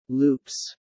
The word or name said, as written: loops